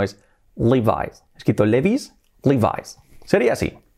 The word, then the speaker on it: Levi's: La penúltima palabra, la penúltima marca es la súper conocida de pantalones vaqueros Levi's. Levi's, que hemos dicho aquí, quiero unos Levi's. Y la pronunciación no se pronuncia Levi's, se pronuncia Levi's. Levi's. Así, fijaos, Levi's. Nada de Levi's, Levi's.